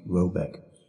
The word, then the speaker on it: Reback: The manor house or herrgard in question is to be called Reback, pronounced something like Roebeck, though that is not its name.